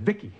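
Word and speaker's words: VICI: V-I-C-I, I, I pronounce it Vicky everyone, Angela Bowen here, the host of She's a Small Wonder, a Small Wonder podcast.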